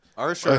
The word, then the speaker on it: Usher: Usher. Usher, if you're from Atlanta. Usher, if you're not from Atlanta.